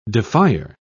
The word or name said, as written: defier